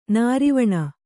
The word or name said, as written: ನಿರ್ವಾಣಿ